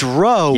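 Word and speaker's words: Ro: It's not Roman, which is like gladiators in the Colosseum. – Aggressive. – It's Roe.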